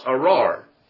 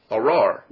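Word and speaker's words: arar (אָרַר): It's Strong's number 0779, and it's pronounced arar, arar.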